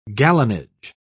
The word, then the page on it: gallonage: British English